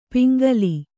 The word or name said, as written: ಪಿಂಗಲಿ